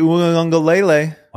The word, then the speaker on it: Uiagalelei: DJ Uiagalelei. – Watch out. Watch out for DJ. And congratulations to you. This has been 3 years of working towards pronouncing that last name.